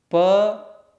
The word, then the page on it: p: sanskrit